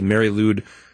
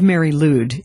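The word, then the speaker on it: Mari Lwyd: I would call it the Mary Lude rap battle.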